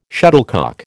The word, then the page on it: shuttlecock: American English